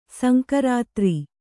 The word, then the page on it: ಸಂಕರಾತ್ರಿ: sankarātri